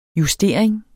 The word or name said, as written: justering